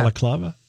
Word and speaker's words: balaclava: Balaclava? – Baklava. Is that how you say it? – Yeah, balaclava.